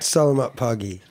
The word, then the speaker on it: Selamat Pagi: and also the owner of the Indonesian-inspired restaurant Selamat Pagi. Pronunciation okay? – Almost in Greenport. – Selamat Pagi. – Pagi, got it.